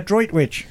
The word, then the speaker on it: Droitwich: Andy from, am I saying this right? Droitwich Spa? – Yeah, Droitwich. – Droitwich Spa. – Yes.